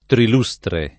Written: trilustre

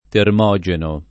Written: termogeno

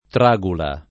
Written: tragula

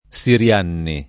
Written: Sirianni

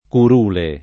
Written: curule

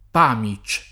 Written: Pamich

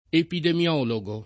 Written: epidemiologo

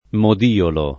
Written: modiolo

